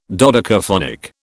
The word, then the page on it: dodecaphonic: British English